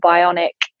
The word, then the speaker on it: Bioniq: called Bionic. And for anyone interested, it's spelled B-I-O-N-I-Q.